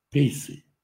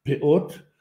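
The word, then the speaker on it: пеот (פאות): Часто при описании еврея говорят «пейсы». Слово «пейсы» – это множественное число слова «пеа» – «край». На современном иврите говорят «пеот». В ашкеназском произношении говорят «пейс» или «паэс», потому что буковку «тав» произносят как «ц», что-то между «ти», «тиэйч» или «с».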